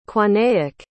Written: cuanaic

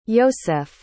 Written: ioseff

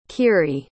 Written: kiri

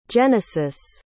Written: genisis

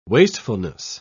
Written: wastefulness

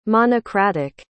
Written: monocratic

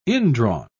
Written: indrawn

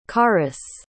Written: carys

